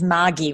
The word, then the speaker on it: Margie: It's the Australian pronunciation is Margie with a hard G.